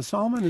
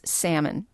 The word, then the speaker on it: Salmon: Is that salmon? Is that the proper way to pronounce it? – I did inquire, and I was told it was salmon, P. – It Chase. – It is salmon.